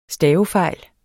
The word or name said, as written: stavefejl